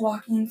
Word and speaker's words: Joaquin: Did I say that right? – Yes, it's Joaquin Phoenix.